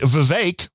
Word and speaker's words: Vivek: which Vivek rhymes with cake he brought up in one of those uh, debates, yes, the last debate.